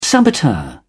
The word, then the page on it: saboteur: British English